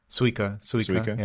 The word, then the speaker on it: Suika: Or how am I saying that right? Suika? – Suika. Yeah.